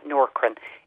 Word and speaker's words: Nourkrin: So the best thing for thinning hair on the scalp is Norkrin. It's spelled N-O-U-R-K-R-I-N.